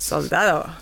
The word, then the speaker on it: Soldado: Soldado.